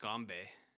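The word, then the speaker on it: ganbei (干杯): What's Cheers uh, in Mandarin is uh, pronounced ganbei.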